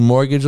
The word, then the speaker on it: mortgage: Growing up in the hood, even though I think I have a few degrees and I was pretty fucking smart, I never knew mortgage was spelled with a fucking T. I was like, where the fuck is the G? Where the fuck is the G at?